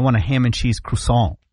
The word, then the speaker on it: croissant: Is it croissant? – Croissant. – Oh. – But that's not the proper pronunciation.